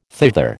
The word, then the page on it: thither: American English